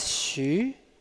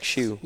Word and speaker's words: Xu: It's Xu. Like... – Xu? – Like Xu. – Yes. – Chen Xu. – Oh, that's it? – Yeah, in Japan, they just call me Xu. So, but yeah, Xu, essentially.